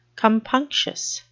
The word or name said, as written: compunctious